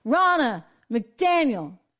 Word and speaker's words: Ronna McDaniel: Official today, Ronna McDaniel, I said it right. I always go for Ronald McDonald for some reason.